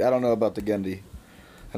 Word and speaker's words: Genndy: I want someone to show up and be like, oh, his name's Gendy. And be like, oh, okay. I'm just waiting for it. It's Gendy.